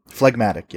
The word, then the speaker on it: phlegmatic: Yeah, I got 86 choleric, 46 sanguine, 46 phlegmatic. How do I say it again? – Phlegmatic, yeah.